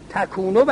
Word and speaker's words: تکونو: الذی صفت عنوانه، الذی تکونو، این نطقه همه یکونوس و غلطه، تکونو باید باشه چون فاعلش حرکات خاصه.